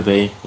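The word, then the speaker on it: Beille: Es war die Etappe zum Plateau de Belle hoch. – Plateau de Bay, ja. Bay, ja. Du weißt doch, dass ich mich nur blamiere bei deiner Aussprache.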